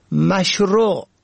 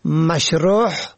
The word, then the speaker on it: مشروع: به گونه مثال مشروع که معنای آن آنچه مطابق شرع است را شماری مشروع یا مشروح به هی حلقی تلفظ می کنند که شرح شده معنا می دهد.